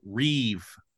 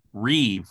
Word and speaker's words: rib: One thing that's very common is what's been dubbed the Reeve pattern. It's spelled R-I-B, but that's coming from the Hebrew word Reeve, which means contention.